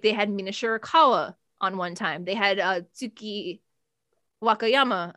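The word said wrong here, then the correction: Waka Tsukiyama: another time. Wakatsukiyama. Am I saying her name right? Yeah. Yes. Yeah. Wakatsukiyama.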